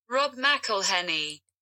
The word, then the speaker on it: Rob McElhenney: Rob McElhenney. Ya sabéis cómo se dice. Como lo he dicho yo.